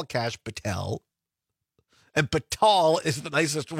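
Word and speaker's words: Kash Patel: Oh, Donna says it's pronounced Cash Patel.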